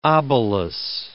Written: obelus

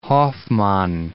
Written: Hoffmann

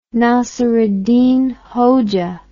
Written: Nasreddin Hoja

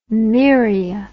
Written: myria

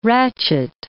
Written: rachet

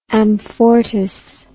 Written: amfortas

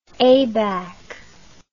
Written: abac

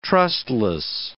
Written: trustless

